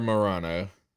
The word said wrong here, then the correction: Marano: Laura Marano.